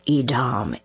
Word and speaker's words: Edom: What is Edom? Who is Edom is the better question. Now, you may know the word as Edom, but I use the Hebrew pronunciation Edom.